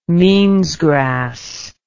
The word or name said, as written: meansgrass